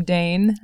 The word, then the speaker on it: Dain: And to your surprise, if you've not met me, the name Dane, you might be expecting a man's voice, but I'm actually a girl. It's spelled D-A-I-N.